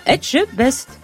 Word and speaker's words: Etchebest: Bonjour, Philippe Etchebest. C'est pourtant pas dur, c'est pas dur à dire. Vous le dites, vous? – Philippe Etchebest? – Oui, c'est ça, Etchebest.